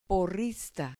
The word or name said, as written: porrista